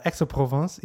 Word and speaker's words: Aix-en-Provence: Aix-en-Provence, yeah, sure.